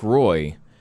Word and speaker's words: Roy: Like Derek Roy, I guess he said at some point that it was Wah, like the goalie. That, like, that is the actual pronunciation of his name. – Oh, really? – But he just, you know, it was like Roy and you roll with it.